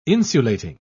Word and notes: insulating: British English

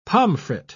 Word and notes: pomfret: British English

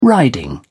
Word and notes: riding: British English

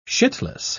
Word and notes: shitless: British English

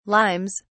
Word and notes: limes: British English

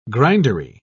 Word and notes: grindery: British English

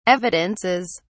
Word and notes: evidences: British English

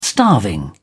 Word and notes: starving: British English

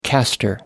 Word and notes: castor: American English

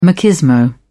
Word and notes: machismo: British English

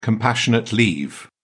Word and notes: compassionate leave: British English

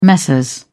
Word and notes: messrs: British English

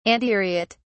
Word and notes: antiriot: British English

